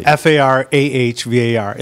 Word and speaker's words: Farhvar: Farhvar. It looks a lot more complicated than it is. – Yeah, I know. – H is silent.